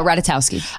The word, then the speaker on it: Ratajkowski: Oh, uh, Ratajkowski.